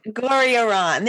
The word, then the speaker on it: Gorriaran: Gorriaran.